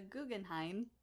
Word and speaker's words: Guggenheim: Lee is a recipient of the Guggenheim.